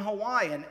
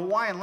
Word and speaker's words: Hawaii: Because it turns out that in the Hawaiian language, every vowel is pronounced on its own. Whereas, you know, in English, we will put them together. So it's like, we say Hawaii, right? Actually, it's Hawaii, because both the I's are pronounced.